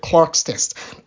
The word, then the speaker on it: Clarke: Clark's test. Clark is spelled C-L-A-R-K-E, Clark's test, right?